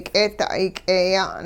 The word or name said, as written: k'eeda'ay k'eeya'n